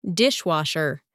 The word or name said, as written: dishwasher